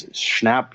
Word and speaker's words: Schnapp: It's Schnapp. He's the one on Stranger Things. – On Stranger Things. – Yeah. Plays Will. – Schnapp. – Got to be Charlie Brown. – Is it Schnapp? – I have no idea.